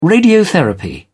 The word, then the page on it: radiotherapy: British English